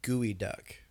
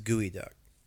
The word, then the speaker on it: Geoduck: Also known as, I mispronounced it. Sorry. Gooey Duck. – Yes. – Is what it's technically called. – It's spelled Geoduck. – It's spelled Geoduck, but it is Gooey Duck.